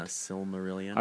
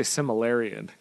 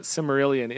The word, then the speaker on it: Silmarillion: Silmarillion, I can never say it right. – Yeah, Silmarillion. – I always say Similarian, because similar. – Yeah. – But Silmarillion, in that apparently there's some, it sounds like the dude before Sauron was way worse, right?